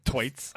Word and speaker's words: Thwaites: Thwaites. With an emphasis on the H.